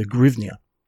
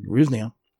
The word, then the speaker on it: hryvnia: The current currency of Ukraine is a hryvnia. Ukrainians will spell it with in Latin letters, they'll spell it with an H. Russians will spell it with a G, the G sound.